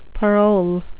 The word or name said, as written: parol